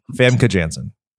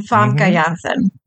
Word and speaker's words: Famke Janssen: Famke Janssen. – Oh, you mean Famke Janssen. That's how you say it.